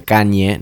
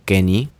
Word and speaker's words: Kanye: que para que sepan se pronuncia Kanye, no Kenny, el loco se enoja bastante cuando le dicen Kenny, eh, porque se postula a presidente de los Estados Unidos.